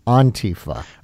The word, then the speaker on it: Antifa: I've heard it so many ways, and you're saying Antifa. – Right. So like in Spanish, you'd say antifascismo, Antifa. So that's sort of a way that, that the Europeans say it, but I don't get in the habit of correcting pronunciation.